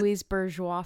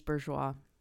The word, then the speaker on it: Louise Bourgeois: Louise Bourgeois fence. – Okay. – She's an artist. Her name is Louise Bourgeois. – Yes. And if you don't know how to pronounce her name, it makes zero sense.